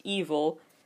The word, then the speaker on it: EVOL: And the fandom then is the Babies, B-A-B-Y, standing for baby always behind you. E-V-O-L, pronounced evil, and it stands for effective voice of ladies. M Black, M-B-L-A-Q, stands for music boys living in absolute quality.